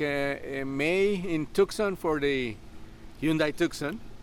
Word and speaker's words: Tucson: tell me how to say it. – The Hyundai Tucson event, yes. – Tucson, okay. – Yes. – And then, there, in Tucson. – There you go, you're on it.